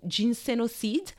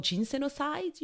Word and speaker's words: ginsenoside: Ginseng has some constituents called ginsenoside, or ginsenoside, you would say in English. Well, let's just go with the French pronunciation, right?